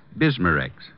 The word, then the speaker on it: Bismarex: That's right, ma'am. BISMA-REX. Bismarex.